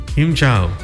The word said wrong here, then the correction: Xin chào: Xin chào. – Xin chào. – Xin chào is hello.